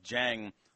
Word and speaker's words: Zhang: Dexter says it's pronounced like Jang, like John with a G.